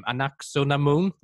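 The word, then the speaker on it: Anck-su-namun: Anaxunamun.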